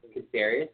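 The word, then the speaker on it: Caceres: J-A-S-O-N-S-C-A-C-E-R-E-S. – C is in cat, not S is in Sam.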